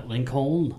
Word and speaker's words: Lincoln: Lincoln. – You didn't Lincoln. – Pronounce the L. I've never seen a silent L before.